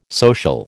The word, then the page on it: social: American English